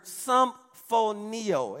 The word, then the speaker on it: sumphoneo: In Greek, it's a word symphonio, and it's spelled S U M P H O N E O.